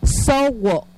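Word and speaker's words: sowo': En lengua yocotán le decimos sowo.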